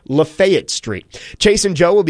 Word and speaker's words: Lafayette: It's Lafayette.